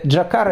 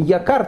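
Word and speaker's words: якар (יקר): Ну, э, Джакар – это еврейское слово «якар», так оно произносится с венецианским акцентом.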